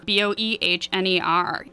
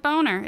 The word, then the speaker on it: Boehner: His name is actually written as Boner, B O E H N E R, but it's not Boner, it's Boehner.